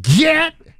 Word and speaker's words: gyat: Get. – Yeah. You got to say it like that. G-Y-A-T.